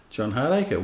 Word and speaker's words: John Hardaker: John Hardaker, well pronounced.